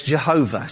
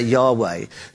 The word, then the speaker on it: Yahweh: English people often pronounce Yahweh as Jehovah. So you might have heard the word Jehovah. Jehovah is my salvation. But more correctly, really, it's to refer to say Yahweh.